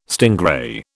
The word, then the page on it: stingray: British English